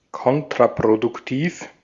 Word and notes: kontraproduktiv: /ˈkɔntʀapʀodʊkˌtiːf/

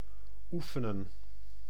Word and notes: former: [fɔʁ.me]; France

